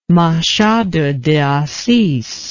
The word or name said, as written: machado de assiz